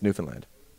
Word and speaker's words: Newfoundland: Yeah, it's Newfoundland.